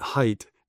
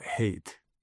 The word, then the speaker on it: Haidt: In fact, he started telling people then, although his name is spelt H-A-I-D-T, pronounce it height, not hate, because he didn't want to communicate hate to people.